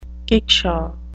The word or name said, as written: kickshaw